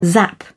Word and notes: zap: British English

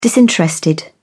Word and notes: disinterested: British English

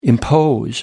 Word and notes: impose: American English

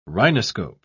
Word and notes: rhinoscope: British English

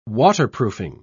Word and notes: waterproofing: British English

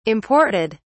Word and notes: imported: British English

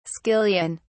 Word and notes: skillion: British English

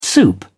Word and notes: soup: British English

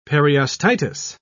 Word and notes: periostitis: British English

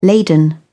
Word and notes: laden: British English

